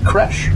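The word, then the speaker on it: crèche: Creech, yes. I believe that's how it's pronounced. It's C-R and then E with a little deal on the top, C-H-E. So Creche, Creech, something like that? – Creche. – Creche, okay. Yes, the steward would say that once you break through that door, it's a running charge.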